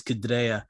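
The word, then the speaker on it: Chidera: I think I said his name wrong the first time. It's Kidrea coming up, and he's a young and upcoming coach.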